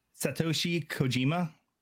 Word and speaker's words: Satoshi Kojima: Satoshi Kojima.